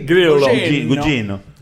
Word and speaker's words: Gugino: No, no, pero se pronuncia así. – Gugino.